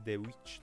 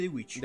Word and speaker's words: The Witch: Ha fatto su questo e The Witch, The Vivi Witch, mi sembra tipo perché è 2V, non W. E eh, si legge The Witch.